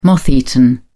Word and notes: moth-eaten: British English

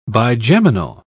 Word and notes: bigeminal: British English

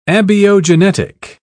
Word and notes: abiogenetic: British English

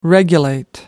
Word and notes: regulate: American English

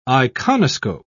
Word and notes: iconoscope: British English

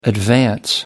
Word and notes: advance: American English

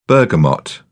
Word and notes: bergamot: British English

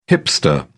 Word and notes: hipster: British English

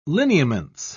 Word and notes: lineaments: British English

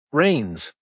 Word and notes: reins: American English

